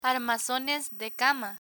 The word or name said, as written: armazones de cama